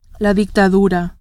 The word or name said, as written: dictadura